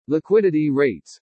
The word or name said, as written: LIQUIDITY RATES